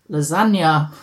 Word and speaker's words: Lesanya: and I'll spell it, L-E-S-A-N-Y-A, pronounced lasagna. As in lasagna.